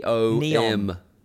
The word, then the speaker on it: NEOM: I love how you call it Neon. – You're putting an N on the end of that? – Yeah, you definitely are. – How do you say it? Why is it always in capitals as well? Everywhere N-E-O-M.